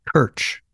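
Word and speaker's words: Krč: Kirch. It's spelled K-R-C. It's a Czech name.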